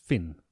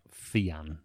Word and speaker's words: Fionn: I just typed in Fionn into Google and it said it's pronounced Finn, not Fionn.